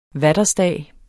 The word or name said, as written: vaterstag